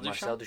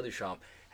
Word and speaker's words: Duchamp: Marcel Duchamp. – Duchamp. – Yeah. – That Duchamp guy. – Duchamp. – Yeah. – Right. – Duchamp. – So Marky Duchamp. please forgive me. Marcel Duchamp has a famous painting called Nude Descending a Staircase.